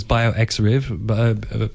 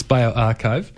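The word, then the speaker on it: bioRxiv: Which I've heard people pronounce as bioxriv. It's bioarchive. It's got a chai or a chai in the middle.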